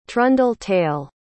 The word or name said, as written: trundle-tail